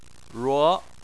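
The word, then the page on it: ruo: tone 2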